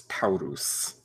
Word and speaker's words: Taurus: I just want to note that you all have actually gotten the name wrong. It is Taurus. It's like a Rush versus Roosh kind of thing, you know? – That ruled.